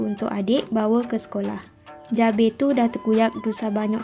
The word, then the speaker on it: jabay: Perkataan seterusnya ialah jabe. Ejaannya J-A-B-A-Y.